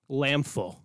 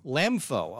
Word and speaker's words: LMFAO: I believe it's pronounced Lamfo. – Lamfo. Oh, okay, excuse me.